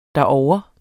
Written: derovre

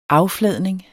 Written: affladning

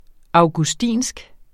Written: augustinsk

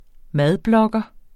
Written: madblogger

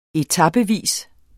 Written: etapevis

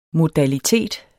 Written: modalitet

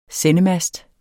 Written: sendemast

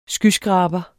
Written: skyskraber

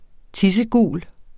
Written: tissegul

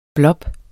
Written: blop

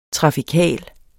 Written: trafikal

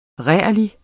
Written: rærlig

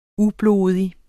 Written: ublodig

